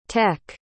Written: teck

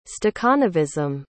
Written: stakhanovism

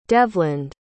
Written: devland

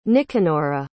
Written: nicanora

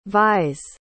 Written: vies

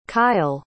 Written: kyel

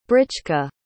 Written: britchka

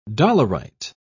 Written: dolerite

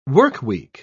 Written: workweek

